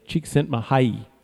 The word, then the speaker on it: Csikszentmihalyi: So from what I understand, I'm going to try to say his name, Csikszentmihalyi.